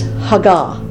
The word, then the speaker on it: hagah: Now, in Hebrew, the word meditate is hagah, H-A-G-A-H, with the accent on this second syllable.